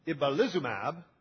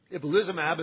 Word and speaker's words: ibalizumab: But I do want to talk about ibalizumab, and I learned that that is the correct pronunciation. I practiced it over and over. Ibalizumab,